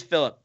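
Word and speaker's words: Filip: We got Flip. That's how you say his name, right? Flip Ingebrigtsen, one of the Ingebrigtsen brothers. – I think it's Philip. I don't know. – Yep. Oh, it is Philip. It's Philip Ingebrigtsen.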